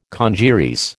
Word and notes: congeries: American English

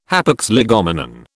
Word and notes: hapax legomenon: British English

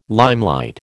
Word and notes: limelight: American English

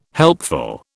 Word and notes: helpful: British English

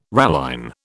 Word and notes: ralline: British English

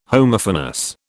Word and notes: Homophonous: British English

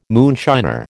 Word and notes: moonshiner: American English

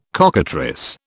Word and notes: cockatrice: British English

